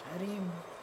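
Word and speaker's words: Harem: Zora has done a number of television series and regular films, some of which are The Long Duel with Yul Brynner, Haram, which they pronounce Harim,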